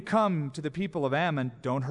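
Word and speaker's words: Amman: It's interesting, the same area, Ammon, is called the same thing today, but we pronounce it Ammon, Jordan.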